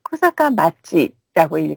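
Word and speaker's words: 코사카 맛지 (小坂町): 카페에서 관광 정보로 소개를 해드렸던 이 코사카 철도 레일 파크가 있는 아키타현 코사카 정, 조금 전에 편지에는 코사카 정이라고 해주셨는데요. 실제로 이 정은 코사카 맛지라고 읽어요.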